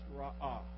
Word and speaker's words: ra'ah: It's a little word we pronounce ra'ah. It's an easy word to pronounce in Hebrew.